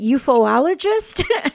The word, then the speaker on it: ufologist: Ufologist? – ufologist is how a lot of people say it.